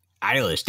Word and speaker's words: Eilish: Eilish, there you go.